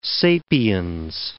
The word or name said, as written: sapiens